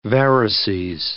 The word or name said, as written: varices